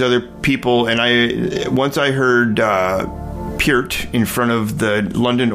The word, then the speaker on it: Peart: Everybody else, Americans pronounce it Peart. – Yeah, it's Peart. Yeah, it's like Peart. Ear.